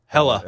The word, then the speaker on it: Hela: Her name is Ella. – Hela. – Ella, yeah. – Is it Hela? – Hela. – Not Hela. Silent H? – Hela.